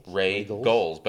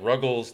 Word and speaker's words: Roegels: Regals. – But it's spelled R-O-E-G-E-L-S. They get Ruggles, they get Rogals, they get pretty much anything but Regals when someone tries to pronounce their name. – Kreitz, which is often pronounced Cruz. Kreitz and Lockhart. – K-R-E-U-Z, Kreitz.